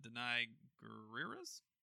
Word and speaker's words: Danai Gurira: Ed, again, has announced another spinoff season series starring Andrew Lincoln's Rick Grimes and Danai Gurira's? Is that how you pronounce her name? – Danai Gurira. – Oh, I nailed it.